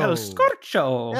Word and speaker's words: El Scorcho: I kicked off with track 1, El Scorcho, or El Scorcho, or how did you say it? El Scorcho. – El Scorcho. – El Scorcho. You rolled the tongue, though. I can't do that.